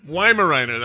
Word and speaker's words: Weimaraner: Weimaraner, that's it.